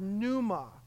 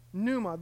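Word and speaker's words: pneuma (πνεῦμα): And in the New Testament, the word that is translated into English spirit is the word pneuma. Pneuma, the P is silent, pneuma.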